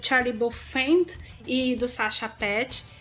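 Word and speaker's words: Paeth: Prestem atenção no nome Sacha Pett, ou Sacha Pett, eu não sei como é que fala, ele é alemão, eu não sei como é que... – Sacha Paef. – Isso aí. Paef. Que é como escreve, crianças.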